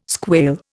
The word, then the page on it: squail: Indian